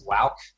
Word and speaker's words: Wowk: Woke is W-O-W-K. If it's easier, you can remember it as Wauk.